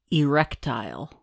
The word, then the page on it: erectile: American English